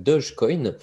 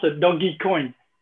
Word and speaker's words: Dogecoin: Très court, je crois qu'on prononce Dogecoin. Et c'est par, euh, dérision, parce que dog, euh, chien, et i comme électronique, et doggy coin.